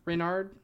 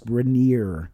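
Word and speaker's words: Raniere: Is Keith Raynard? – I think it's pronounced Rainier. – Rainier. – Keith Rainier.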